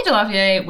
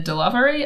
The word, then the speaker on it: Delavier: Stormy Delavier was born in 1920 in New Orleans, and I'm just going to say now, their surname is pronounced Delavier. You will hear it said Delavery a fair bit because that is how it's spelt, but Stormy themselves says Delavier.